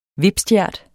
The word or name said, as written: VIPSTJERT